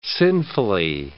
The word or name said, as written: sinfully